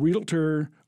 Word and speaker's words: realtor: Remember, penultimate means second to last, and realtor only has two syllables, and there's no such word as orientate.